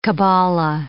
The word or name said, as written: kabbalah